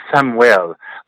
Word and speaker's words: Samwell: My name is Samuel, but years ago when I met my best friend, we were study abroad students in the Dominican Republic, I loved the name. They would pronounce my name Samwell, and I just thought it was, I don't know, I thought it was hot, it was sexy.